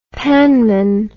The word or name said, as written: panman